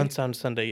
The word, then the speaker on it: UnSound Sunday: Am I saying that correctly? UnSound Sunday? – UnSound Sunday, yes.